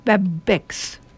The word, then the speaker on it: Babx: And then the next one, when Christophe was here, he told me how to pronounce it. B-A-B-X. And it's pronounced Babex.